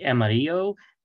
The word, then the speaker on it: Amarillo: That's how I pronounce it, by the way, Amarillo College. How do you pronounce it? – We say Amarillo.